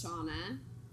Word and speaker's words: Shonna: So my Instagram name is Shonna. – Spell it for them. – I know. S as in Sam, H-O-N-N, so Nancy, A, 619 Education.